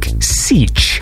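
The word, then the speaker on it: Sietch: So I was saying it's Seich this whole time because it's S-I-E-T-C-H, but I got a letter from this person and he says, "Hey, you asked me how to pronounce my donation name. Have you ever read Dune by Frank Herbert? Seich is the home of the Fremen, a community or village, and is derived from siege. So its pronunciation would be more like Seich.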